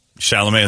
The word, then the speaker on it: Chalamet: Chalamet is the one? Yeah. I thought he'd be... Because chicks are digging on that whole skinny weirdo look. – You pronounce it like challah bread? – Yeah, like challah bread.